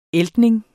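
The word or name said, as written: æltning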